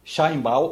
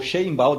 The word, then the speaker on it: Sheinbaum: Cláudia é a presidente do México, Cláudia, Cláudia Sheinbaum, ou Sheinbaum, dependendo da vontade que você tenha para pronunciar um nome que é judeu.